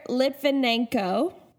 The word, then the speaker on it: Litvinenko: So Alexander Litvinenko, that's how I am choosing to pronounce it.